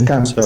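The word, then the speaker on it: Kahndaq: But I was saying, maybe it's not actual Karnak, because he pronounced it as Kandak. – It is Kandak.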